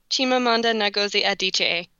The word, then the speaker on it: Chimamanda Ngozi Adichie: I'm going to butcher the second name, but Chimamanda Ngozi has an Adichie? Chimamanda Ngozi Adichie. – Thank you. I'm not going to try to replicate that, but that was I appreciate your pronunciation.